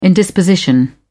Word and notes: indisposition: British English

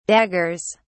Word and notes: daggers: British English

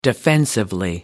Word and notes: defensively: British English

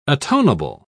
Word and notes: atonable: British English

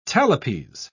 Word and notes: talipes: British English

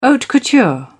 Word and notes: haute couture: British English

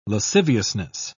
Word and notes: lasciviousness: British English

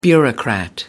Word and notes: bureaucrat: American English

